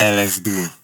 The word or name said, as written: LSD